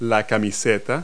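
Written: undershirt

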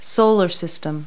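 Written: solar system

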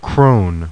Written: crone

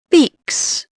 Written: beaks

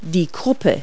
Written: croup

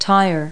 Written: tire

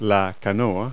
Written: canoe

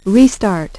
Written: restart

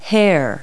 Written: hair

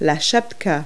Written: schapska

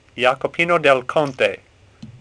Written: Jacopino

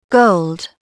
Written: gold

